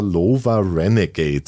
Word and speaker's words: Lowa Renegade: aber ist schon der Lowa Renegade, heißt das so? Ja. Spricht man das richtig ganz aus? Genau.